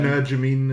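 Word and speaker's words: Energumene: uh, of course, Shishkin will uh, go up against Engerman, Engermain. – Um, Energamine is often how it's pronounced.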